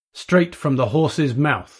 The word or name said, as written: straight from the horse's mouth